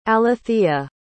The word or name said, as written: alethea